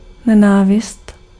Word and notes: haat: IPA: /ɦaːt/

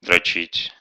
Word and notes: tease: /tiːz/

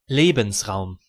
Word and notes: Lebensraum: /ˈleːbm̩sˌʀaʊ̯m/; /ˈleːbənsˌʀaʊ̯m/

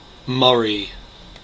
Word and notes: Murray: /ˈmʌɹi/; American English

